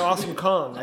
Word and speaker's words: Awesome Kong: Maybe he's driving the awesome dong. Or the Awesome Kong. I said that wrong.